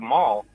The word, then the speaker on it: Maule: M-A-U-L-E, how do you pronounce that street? – You know, I never knew. I always said Mall because I heard everybody else say Mall, and then I saw the street name. And I was like, that doesn't look like Mall.